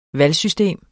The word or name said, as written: valgsystem